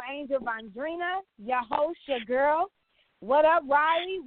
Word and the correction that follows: Raleigh: It's Raleigh, okay? Right, not Riley. – Listen. – It's not a children's name. Not R-I-R-A.